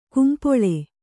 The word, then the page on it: ಕಂಪಳ: kampaḷa